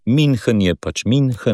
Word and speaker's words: München: medtem ko ü se izgovori kot i, torej München, Münchenski sporazum.